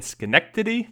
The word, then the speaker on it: Schenectady: And Schenectady, did I pronounce that correct? Yep.